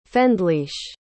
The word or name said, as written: fendliche